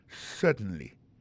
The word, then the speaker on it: certainly: certainly alors en parlant de accent gardez bien pour un mot certainly accent tombé sur premier syllabe là yes certainly témoin point pour encore yes certainly comprenez accent tombé sous premier syllabe là.